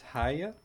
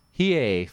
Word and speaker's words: Hiei: Number one is, I don't know how to pronounce this, Hiya? – Hiei from Yu Yu Hakusho.